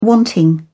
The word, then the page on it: wanting: British English